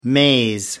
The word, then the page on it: maze: American English